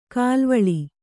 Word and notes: ಕಲುವಳೆ: kaluvaḷe

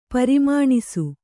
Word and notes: ಪ್ರಮಾಣಿಸು: pramāṇisu

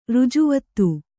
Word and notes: ರಾಜವೀಥಿ: rāja vīthi

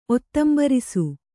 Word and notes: ಒತ್ತಂಬರಿಸು: ottambarisu

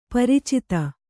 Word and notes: ಪರಿಚಿತಿ: pariciti

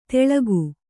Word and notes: ತೆಳಗು: Kannada

